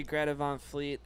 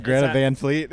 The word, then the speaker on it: Greta Van Fleet: I love that you're mispronouncing their name, too. It's even it's Greta Van Fleet. It's so Greta, I thought it was Greta Von Fleet. – It's with an A. – It's Van Fleet, it's Van, like Ludwig Van Beethoven? – No, that's Von.